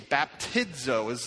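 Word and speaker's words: Baptizo (βαπτίζω): Baptizo is kind of the way to pronounce that.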